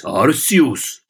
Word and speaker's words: Arceus: Wie spricht man es richtig aus? – Naja, richtig. Ich sage so wie der Japaner im Video, also bitte, das ist keine Verarschung. Pokémon Legends Arceus.